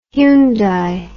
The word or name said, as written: hyundai